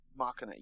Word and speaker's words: Machina: I'm pronouncing it. – Vox Machina. I think. – Machina. Yes.